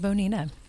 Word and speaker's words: Bonita: Did I say it right? Bonita? – You did. – You did. – Bonita.